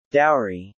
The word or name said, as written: dowry